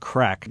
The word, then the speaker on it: craic: Well, you know, a session's just called a session, but the Irish have a word crack. You know, you can spell it C-R-A-C-K or C-R-A-I-C.